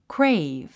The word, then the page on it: crave: kréiv; クレイブ